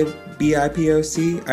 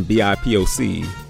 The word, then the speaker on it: BIPOC: I have no idea how to say BIPOC. I've heard it pronounced BIPOC, like Tupac, but I just woke up one day and the whole internet was using it.